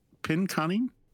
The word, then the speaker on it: Pinconning: Hey, that city town in Michigan that's spelled P-I-N-C-O-N-N-I-N-G. How do you pronounce that? – Wait, the spelled what? – P-I-N. – P-I-N. – C-O-N-N. – C-O-N-N. – I-N-G. – Pincon. Pinconing? Pinconing? – That's right.